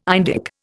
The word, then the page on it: Indic: Indian